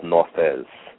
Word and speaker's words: Nauthiz: It's called Nauthiz, which I might be mispronouncing, but N-A-U-T-H-I-Z.